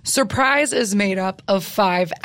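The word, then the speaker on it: 5urprise: So it's a group that is technically pronounced Surprise. We call them Five Prize or Fur Prize because it's spelled with the number five instead of a capital S. So it's just Surprise, but it's a five, U-R-P-R-I-S-E.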